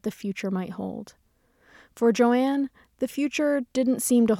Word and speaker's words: Joan: On her birth certificate, her name was Joan, J-O-A-N, but she preferred to pronounce it Joanne.